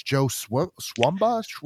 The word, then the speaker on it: Joe Swanberg: But yeah, Ty West and all of his friends, I always say his name wrong, but it's Joe Swanberg.